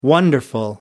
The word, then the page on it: wonderful: American English